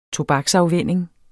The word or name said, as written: tobaksafvænning